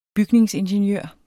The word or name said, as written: bygningsingeniør